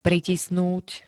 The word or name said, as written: pritisnúť